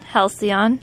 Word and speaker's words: Halcyon: And Halcyon, that's what they did. – Right, I never can pronounce that word, so I just... – Just try it, try it. – Halcyon. – There you go.